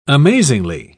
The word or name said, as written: amazingly